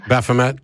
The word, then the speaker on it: Baphomet: So we're neither the Ten Commandments monument nor the Satanic Temple bench to piss people off, or the how's it pronounced, the Baphomet statue? – Baphomet. – And Baphomet. Okay.